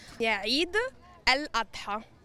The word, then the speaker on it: Eid el-Adha: Eid el-Adha.